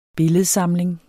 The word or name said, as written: BILLEDSAMLINGER